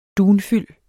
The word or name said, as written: dunfyld